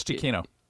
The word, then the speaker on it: Giacchino: Michael Giacchino? – Giacchino. – Correct, you got it. – It's Giacchino. – Is it Giacchino? – I watched the documentary okay.